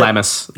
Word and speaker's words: Lembas: The moment where Legolas takes a bite of the Lembas bread. Is that how you pronounce it? – Lammas. Yeah, Lammas bread.